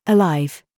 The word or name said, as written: PRICE